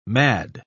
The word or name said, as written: mad